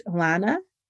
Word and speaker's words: Lana: I'm at Hey Coach Lana, and my name is spelled L-A-N-A. So reach out to me on Instagram, send me a DM. I'm on Facebook as Lana Hernandez, but I don't really Facebook very much.